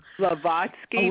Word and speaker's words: Blavatsky: Blavatsky.